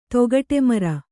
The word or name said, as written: ತೊಗಟೆಮರ